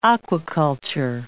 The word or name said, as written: aquaculture